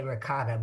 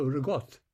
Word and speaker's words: ricotta: No, that's how it's spelled, ricotta. But my people come from southern Italy, Amalfi, and they don't say the last vowel for some reason. So they call it ricotta.